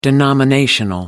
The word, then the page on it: denominational: American English